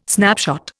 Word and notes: snapshot: Indian

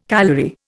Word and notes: calorie: Indian

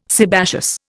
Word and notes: sebaceous: Indian

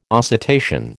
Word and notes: oscitation: American English

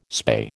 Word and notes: spay: American English

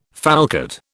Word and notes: falcate: British English

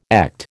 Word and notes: echt: American English

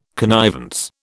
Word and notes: connivance: British English

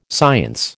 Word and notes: science: American English

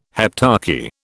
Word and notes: heptarchy: British English

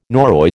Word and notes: Norroy: American English

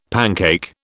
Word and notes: pancake: British English

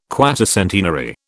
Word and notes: quatercentenary: British English

